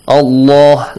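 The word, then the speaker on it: الله: Pronounce the word Allah with a heavy sound. Allah, like this.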